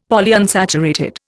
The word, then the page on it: Polyunsaturated: Indian